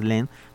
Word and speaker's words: Lesne: Se llama Gerard, eh, se escribe Lesne, lo pronunciamos Len, pero es Lesne, eh, Gerard Lesne Ombra Maifu así se llama el disco.